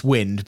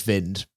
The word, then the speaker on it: Vind: Uh, I really want to call him Jonas Wind, but I know it's Jonas Vind.